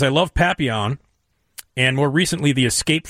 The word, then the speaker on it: Papillon: Papillon.